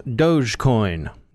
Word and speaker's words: Dogecoin: Witness Dogecoin, named after a dog but not pronounced like dog because it came from an old meme that originated in the Homestar Runner puppet show, where Homestar Runner calls Strongbad his Doge.